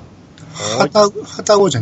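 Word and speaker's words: 旅籠: はたごじゃないですか。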